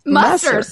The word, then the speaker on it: Musser: Musser.